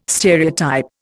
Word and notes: stereotype: Indian

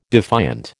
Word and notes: defiant: American English

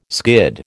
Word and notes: skid: American English

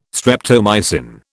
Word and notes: streptomycin: British English

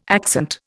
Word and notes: accent: Indian

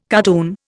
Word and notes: cardoon: Indian

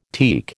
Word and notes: teak: American English

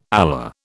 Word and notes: Allah: British English

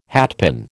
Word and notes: hatpin: American English

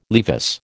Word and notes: Levis: American English

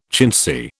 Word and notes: chintzy: British English

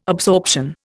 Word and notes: absorption: Indian

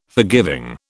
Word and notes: forgiving: British English

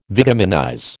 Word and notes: vitaminize: American English

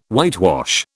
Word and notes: whitewash: British English

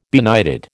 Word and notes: benighted: American English

